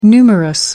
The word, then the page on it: numerous: American English